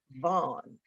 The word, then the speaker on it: VON: And in October 2020, she oversaw the launch of Midway Broadcasting's new OTT digital streaming platform, VON TV, pronounced Vaughn, which can be seen in more than 100 million households on Roku, Apple TV Plus, Amazon Prime, and at VON.TV.